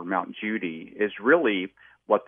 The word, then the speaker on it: Judi: There's also Mount Judi, or over there they call it Kuti because they can't pronounce a J. They pronounce it with a C or a K.